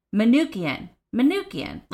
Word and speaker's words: Manookian: Saint and God Brian McCunakin. I'm going to spell that one because I did not say it right. M-A-N-O-O-K-I-A-N. Manukian. Like, that was not that hard. I just had to spell it first.